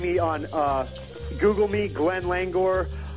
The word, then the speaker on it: Langohr: Okay, the last name is spelled L-A-N-G-O-H-R, Langor, Glenn Langor.